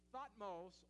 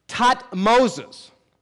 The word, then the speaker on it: Thutmose: This could be Thutmose or Thutmose, but it also is pronounced Thutmoses. Sometimes the H is silent, and it's Thutmoses.